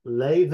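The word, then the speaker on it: Laufey: This artist is called Leve. Let me spell it for you, dear listeners, because the way you pronounce it is not how you would think based on the spelling. The spelling is Lauftey, Leve.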